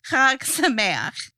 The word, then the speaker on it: Chag Sameach: Chag Sameach. – Chag Sameach.